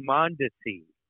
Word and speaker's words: Mondesi: Alberto Mondesi, that's how it's pronounced. – Mondesi. – Yeah, we're like Raul Mondesi.